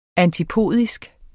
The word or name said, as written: antipodisk